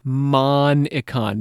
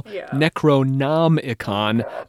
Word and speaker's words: Necronomicon: It is a malapropism of H.P. Lovecraft's fictional Necronomicon of Alhazred.